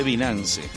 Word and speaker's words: Binance: Yo decía antes Binance, pero la pronunciación en inglés, Binance.